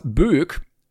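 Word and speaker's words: Bög: Im Reddit-Forum hat nämlich einer geschrieben, Moin, ich wollte euch nur mitteilen, dass Böck mit einem Ö, also B-Ö-G, das schwedische Wort für Schwuchtel bzw. schwul ist. Hat aber meines Wissens nach eine andere Etymologie. Wird aber genauso ausgesprochen wie im Podcast und ich stand in Schweden im Supermarkt und höre über meine Kopfhörer Böck.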